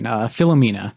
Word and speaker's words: Philomena: Philomena.